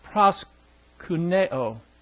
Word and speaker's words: proskuneo: That word is proskuneo. P-R-O-S-K-E-U-N-E-O. P-R-O-S-K-E-U-N-E-O. Pronounced proskuneo. And which means to express in attitude or gesture one's complete dependence on or submission to a high authority figure.